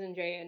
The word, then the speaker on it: Andrea: But in Polish, like, if you're speaking about Andrea or to Andrea or about something of Andrea's, like, the pronunciation of my name would change because the ending would change.